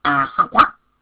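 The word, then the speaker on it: āsava: Phạn ngữ Pali á đọc là asawa, asawa dịch được dịch ra là lậu hoặc.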